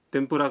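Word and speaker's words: تیمپورا (天ぷら): تیمپورا کا تلفظ ہے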